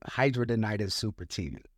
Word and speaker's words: hidradenitis suppurativa: You know, the craziest thing, man, we say HS for short, but it's called hidradenitis suppurativa. – I was going to say, how do you say it? – Hidradenitis suppurativa. – Hidradenitis super? – Suppurativa. – Suppurativa. Hidradenitis suppurativa.